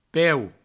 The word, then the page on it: peu: Provençal